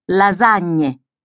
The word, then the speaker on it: lasagne: Listen to Italians saying spaghetti, lasagne, or pizza margherita. You will also notice that they usually stress the syllable before the last. Spaghetti, lasagne, margherita.